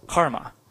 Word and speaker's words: CCRMA: Proper pronunciation of CCRMA is Karma.